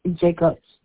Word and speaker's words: Jacobs: Jacobs. J-A-C-O-B-S.